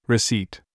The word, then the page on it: apron: English